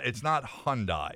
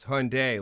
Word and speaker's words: Hyundai: It's not Hyundai. – It's Hyundai, like Sunday. – Exactly, right. Like Sunday. – That's right. – Yeah. I heard a dealer once call it Hyundai, and I said to myself, I'd never buy a car from that person.